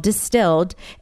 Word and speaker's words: DSTLD: I'm really excited about this brand that now I'm I've been talking about for a little bit called Distilled, except it's kind of confusing. They're called Distilled, but it's spelled D-S-T-L-D. Okay, so when you go to their website, it's D-S-T-L-D.